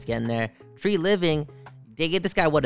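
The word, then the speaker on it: Treliving: Well, didn't they give how do you say that GM's name again? Treveling. – Speaking of the Lorax and trees and air and living, it's Tree Living. – Tree Living.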